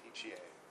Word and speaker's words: petechiae: These people get a whole bunch of tiny little bruises, which we call petechiae. Did I say that right? Petechiae. That word there. Tiny, tiny little bruises all over the place. You get little speckly bruises. Petechiae, thank you.